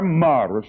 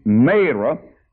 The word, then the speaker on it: Marah: When they came to Marah, or Mara, some people call it Mara, but my Bible said it's a long A, Marah.